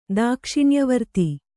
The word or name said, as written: ದಾಕ್ಷಿಣ್ಯವರ್ತಿ